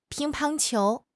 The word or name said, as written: ping1 pang1 qiu2